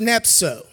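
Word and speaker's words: nepho: It's N-E-P-H-O, but it's pronounced nepso.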